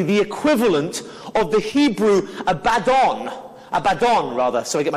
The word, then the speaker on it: Abaddon: I always get the emphasis on the wrong, get the emphasis on the wrong syllable. It's, um, it's Abaddon. I always say Abaddon, but it's Abaddon.